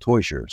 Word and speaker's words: Teuscher: And the way to remember it is, it's called Toysher. So, want a toy? Sure. – That's how you pronounce it.